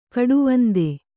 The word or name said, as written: ಕಡುವಂದೆ